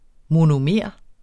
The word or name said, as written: monomer